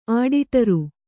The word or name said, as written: ಆಡಿಟರು